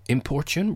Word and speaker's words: importune: Importune rhymes with fortune, not cartoon,